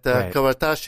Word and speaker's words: Karataj: Yeah, I'll get used to it by the end of the show, you know, because the spelling is not quite the way you pronounce it, but Karataj it is.